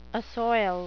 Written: assoil

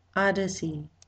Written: odyssey

